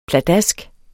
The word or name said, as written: pladask